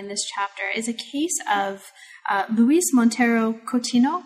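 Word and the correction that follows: Coutinho: Am I pronouncing that? – Cotinho.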